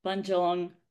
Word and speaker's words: Bundjalung: I am not sure if I pronounced your heritage, your proud Bundjalung heritage.